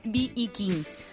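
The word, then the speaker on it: B.B. King: Él tocó con grandes como Aretha Franklin o con B.I. King. – ¿Cómo? – B.I. King. – ¿Con quién ha dicho? – Te juro, es que me lo apuntado. Sí, y yo sé que pero... se dice Viking.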